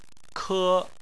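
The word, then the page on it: ke: tone 1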